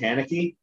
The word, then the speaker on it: Haneke: I pronounce it Haneke, but it's, yeah. I think it's Haneke, like Nietzsche.